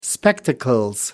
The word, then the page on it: spectacles: British English